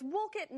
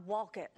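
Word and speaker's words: Wolcott: It is Berlin, not Berlin, Coventry, not Coventry, and it's Woolkit, not Walkit.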